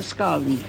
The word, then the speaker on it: scone: Always call it a scone. – Probably because of where you're from, but the correct pronunciation for when scones were first invented is as in S-K-O-N.